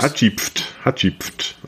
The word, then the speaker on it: Hatschepsut: Hatschepsut.